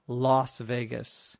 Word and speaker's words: Las Vegas: The bachelor party then is going to be in Vegas, V-E-G-A-S, and Vegas stands for Las Vegas, which is spelled L-A-S, although we usually pronounce it loss, like an O. So L-A-S-V-E-G-A-S.